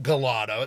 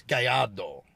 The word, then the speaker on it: Gallardo: It's not Gallardo, it's Gallardo.